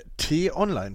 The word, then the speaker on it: T-Online: T-Online, tatsächlich.